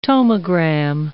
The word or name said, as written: tomogram